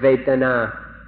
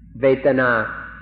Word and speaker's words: vedana: So we'd like to request, we implore you, in fact, to remember this word vetana, vetana, or in Thai, vetana. It's spelled in Roman letters V-E-D-A-N-A, vetana or vetana.